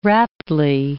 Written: raptly

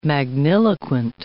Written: magniloquent

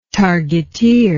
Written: targeteer

